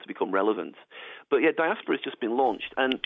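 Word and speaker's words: diaspora*: It's one that I believe we're pronouncing diaspora, even though they spell it with a little asterisk at the end of the name. I think that's silent.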